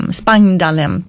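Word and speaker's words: Spangdahlem: Det här hände nära flygplatsen Spangdalhem på tyska, ska det uttalas.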